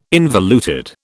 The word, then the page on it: involuted: British English